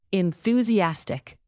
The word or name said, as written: enthusiastic